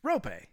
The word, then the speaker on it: Roope: Roope Hintz. It's Rope.